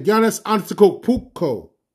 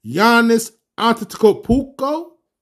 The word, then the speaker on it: Giannis Antetokounmpo: It's like Giannis Antetokounmpo from the Milwaukee Bucks. It took me a year to get his name right. Giannis Antetokounmpo.